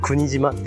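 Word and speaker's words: 柴島: そうそう、芝犬の芝に犬じゃないわ。島って書いて、国島って読むところ。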